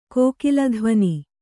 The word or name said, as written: ಕೋಕಿಲಧ್ವನಿ